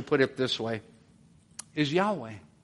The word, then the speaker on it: Yahweh: That would be one way of pronouncing those four letters. There were no vowel pointings originally in the Hebrew Old Testament. Most people today would believe, actually, a more accurate way to pronounce it would be Yahweh.